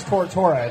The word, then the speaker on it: Tortora: Because mine's Tortora.